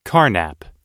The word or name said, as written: carnap